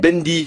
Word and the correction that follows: Bindi: je prononce bien ou pas ? – Très Bindi, oui, Bindi.